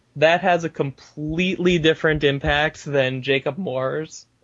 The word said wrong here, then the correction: Maurer: Or how do we say his name? – Jacob Maurer. – Maurer. Jacob Maurer's.